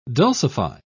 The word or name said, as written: dulcify